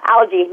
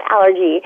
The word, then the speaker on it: algae: I don't have the name in front of it, but they do make, there are certain particular algae, is that how you say it? Yeah, algae, not allergy.